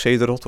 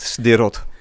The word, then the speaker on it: Sderot: Sderot, wat hier altijd als Cederot wordt uitgesproken, maar het is toch echt Sderot.